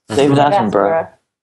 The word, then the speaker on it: Attenborough: David Attenborough.